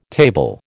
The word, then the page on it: table: American English